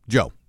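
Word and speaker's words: João: Is it Joe Diaz? Is that how you say his name? – I believe so, yes. – J-O-A-O? – Joe.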